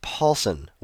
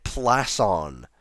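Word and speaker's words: Pålsson: His name is pronounced Paulson, which, now that I look at his name, seems pretty obvious that that's how one would say it, and the way I was pronouncing it was pretty absurd.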